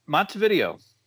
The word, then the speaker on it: Montevideo: I'm good. It's Montevideo. – Montevideo. Okay. Yep. I figured it was more of the Spanish pronunciation, but it is Minnesota.